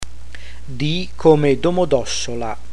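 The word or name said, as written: D come Domodossola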